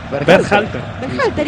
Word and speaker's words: Berhalter: Sebastián Ber... ¿Cómo es el apellido? – ¿Cómo? – Dígalo usted. – Berhalter, ¿y qué dije yo? – No, impecable.